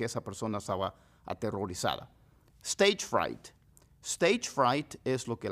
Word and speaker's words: fright: Fright, como diciendo French fries, papas fritas, fries, pero va a decir fright.